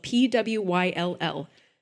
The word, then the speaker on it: Pwyll: Pwyll is exactly I I swear that's how you pronounce it. It's spelled P W Y L L. Pwyll.